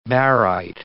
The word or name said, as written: baryte